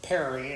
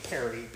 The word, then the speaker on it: Paré: My name is Kurt Perry, and it's spelled with a K, U-R-T, and the last name is Paré, P-A-R-E, although I just say Perry because that doesn't sound so Frenchy.